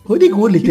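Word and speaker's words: Ruud Gullit: Rudy Gullit, é exatamente assim que fala.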